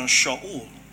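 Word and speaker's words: Shaul (שאול): Yeah, Paul was his recognized pronunciation of his name Saul, or the Hebrew pronunciation Shaul, which is how it's, you know, pronounced in Hebrew.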